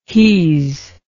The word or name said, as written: he's